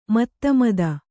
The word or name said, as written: ಮಧುಮತ್ತೆ